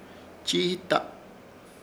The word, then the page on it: Chiita: Ishir Chamacoco